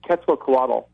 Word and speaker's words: Quetzalcoatl: I think it's Quetzalcoatl.